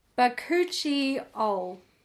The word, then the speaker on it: Bakuchiol: And then we have the, SpaghettiOl, I don't know how to pronounce this. It's a 1% Bakuchiol. There we go.